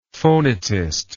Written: phonetist

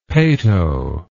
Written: peto

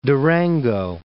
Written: Durango

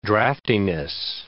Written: draftiness

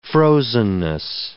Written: frozenness

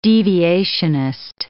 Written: deviationist

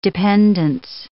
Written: dependence

